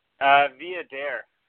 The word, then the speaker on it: Viader: Viader. If you dare. – Be a dare. So Mike's right. The emphasis is on dare.